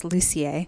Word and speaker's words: Lucier: No, it's French-Canadian, so it's Lucier.